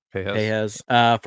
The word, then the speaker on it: Pages: And Andy Pages, I think is how you pronounce the last name. I've been told it's something different. – Pejas.